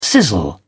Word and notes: sizzle: British English

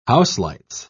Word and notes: houselights: British English

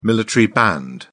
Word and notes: military band: British English

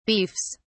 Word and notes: beefs: British English

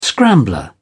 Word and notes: scrambler: British English

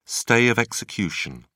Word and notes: stay of execution: British English

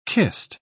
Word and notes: kist: British English

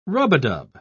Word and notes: rub-a-dub: British English

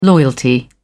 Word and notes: loyalty: British English

